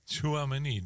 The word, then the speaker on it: Tchouaméni: Want die twee Valverde en Tchouaméni. Zeg ik dat goed? – Tchouaméni.